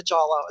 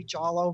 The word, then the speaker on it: Jollo: Hi, my name's Linda Jolo. It's spelled J-O-L-L-O. And the way to remember it is it must be Jolo because Jello doesn't shake like that.